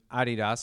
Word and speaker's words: Adidas: And then not too long before that, to in-house at Reebok, which for a time was owned by Adidas, or as I've learned, is the German pronunciation Adidas, since it's a German company.